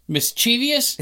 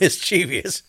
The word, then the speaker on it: mischievous: Mischievous? – It's not mischievous. It's mischievous.